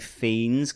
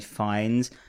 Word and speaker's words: Fiennes: I was pronouncing his name wrong. I've always said Ralph Fiennes because that's what someone told me about. It's Ralph Fiennes.